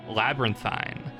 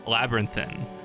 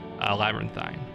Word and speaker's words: labyrinthine: But we, as uneducated Philistines, pronounced it Labyrinthine. We've been informed by a very helpful listener here today that you pronounce it much like serpentine, labyrinthine. Wait, no, I've misread that. Labyrinthine. Like serpentine? Yeah, that's right.